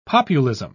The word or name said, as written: populism